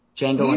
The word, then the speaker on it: Django: I said he's in Django Unchained, and then said the D is silent. But that's what Jamie Foxx says.